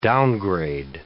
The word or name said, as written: downgrade